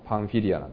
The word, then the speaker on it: パンフィリア: ホームシックになったのか、いろいろ目の前で起こっていることに耐えられなくなったのか、飽きちゃったのか、それは分かりませんけれども、まあ、いずれにしてもこの、ここに地図があるんですけどね、あそこに赤い丸がついてパンフリアと書いてあるの、まあ、パンフィリアなんですけれども、最初の宣教旅行はあのアンティオキアからキプロスという島に渡って、それからパンフリアに行ったと書いてあるんですね。